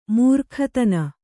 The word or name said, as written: ಮೃಗಾದನ